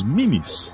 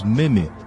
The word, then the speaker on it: meme: Especificamente sobre o episódio 25, eles falaram dos mimes de internet. A pronúncia é mime. É que nós brasileiros falamos meme, mas o correto é mime.